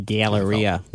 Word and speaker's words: Galleria: The Galleria. You got to say it like that. That's the Galleria? – That's how they say it in Buffalo.